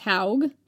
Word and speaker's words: Haug: Calling the mound Howe would make perfect sense, as Haug, pronounced Haug, like Smaug, is Old Norse for a mound. – To me, it looks more like haug, like a hog, but you know.